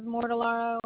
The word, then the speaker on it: Mortellaro: Andrea, A-N-D-R-E-A. Last name is Mortellaro, M-O-R. T-E-L-L-A-R-O.